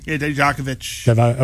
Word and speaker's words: Dijakovic: Adam Cole, Keith Lee, Shane Thorne, Dominic, I can't, how do you say it? Dijovatsky? – Dijakovic.